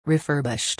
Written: refurbished

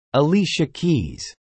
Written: Alicia Keys

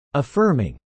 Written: affirming

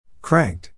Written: cranked

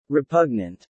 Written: repugnant

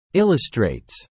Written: illustrates

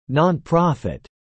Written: nonprofit

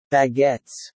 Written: baguettes

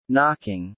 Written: knocking

